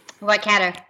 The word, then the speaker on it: Waikato: So we're going back with Dr. Sims, who is at the University of Waikato. I hope I pronounced that correctly. – Waikato.